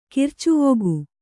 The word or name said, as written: ಕಿರ್ಚುವೊಗು